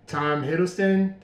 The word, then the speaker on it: Hiddleston: With Tom Hiddleston.